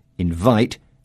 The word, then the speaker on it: invite: But if I say to Catherine, I want to invite you to my party on Saturday, invite is a verb with the stress falling on the second syllable.